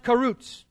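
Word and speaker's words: karutz (חָרוּץ): Diligence in the Hebrew, it's pronounced karutz.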